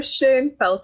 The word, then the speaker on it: Schoenfeld: It's Lara Schoenfeld. It's actually a German surname, but don't worry, all the South Africans say it like that.